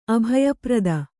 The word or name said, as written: ಅಭಯಪ್ರದ